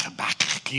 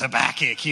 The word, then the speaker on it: Habakkuk: You got to say it like Habakkuk, you know, like you got tobacco in your mouth, Habakkuk, you know.